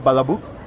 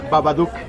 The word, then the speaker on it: Babadook: ¿Badabook? – El que ya está en Netflix precisamente, el Netflix. Babadook.